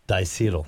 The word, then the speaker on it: diacetyl: Now, is it diacetyl? Am I saying that correctly there? – Diacetyl.